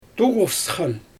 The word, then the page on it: tuɣufsxan: Xyzyl